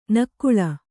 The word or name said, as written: ನಕಲಿ